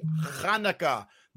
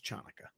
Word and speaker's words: Hanukkah: Happy Hanukkah. The C is silent. It's not pronounced Chanukkah.